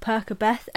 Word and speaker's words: Percabeth: The friendship of Perkabeth, and yes, that is the right way to say it,